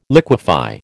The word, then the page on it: liquefy: American English